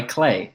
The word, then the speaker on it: Clay: It's a game by Clay. It rhymes with play.